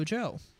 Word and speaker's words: Djo: Well, yeah, that's how you say it. DJO, Joe.